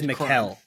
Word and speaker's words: Mikal: His name is Mikal.